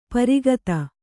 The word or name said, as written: ಪ್ರಘಾತ